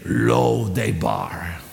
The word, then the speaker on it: Lo-debar: And Lo-Debar, am I saying that correctly? Actually, you should say it, Lo-Debar.